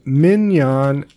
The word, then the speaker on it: minyan: And it's called a minyan, M-I-N-Y-A-N. – Okay. – So you pronounce it minion or minyan? – Minion, I was, yeah.